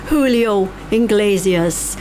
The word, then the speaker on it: Julio Iglesias: She said she likes Julio, I should say it properly, Julio Iglesias.